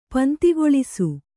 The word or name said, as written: ಪಂತಿಗೊಳಿಸು